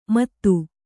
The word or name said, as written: ಮದು